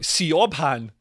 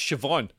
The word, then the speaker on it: Siobhan: Irish people, you meet somebody, you see their name, it's like Siobhan. It's like, oh, hello, Siobhan. And they're like, no, it's Siobhan. And it's like, well, why have you spelt it Siobhan?